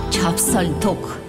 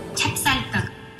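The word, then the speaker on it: Чапсальток (찹쌀떡): Только спрашивать надо правильно, произнося чипльдук по-корейски. Чапсальток. – Чапсальток.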